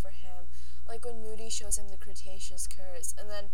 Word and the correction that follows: Cruciatus: Oh, they pronounce it Cruciatus.